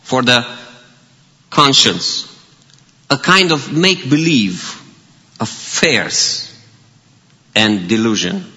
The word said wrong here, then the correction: farce: I don't know if I pronounce it well. Fears. F-A-R-C-E. Farce. Farce, yes.